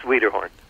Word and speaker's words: Wiederhorn: Jon, you say your name Whiterhorn or Wiederhorn? – Uh, it's Wiederhorn.